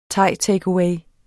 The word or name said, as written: thaitakeaway